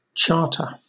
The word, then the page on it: charter: British English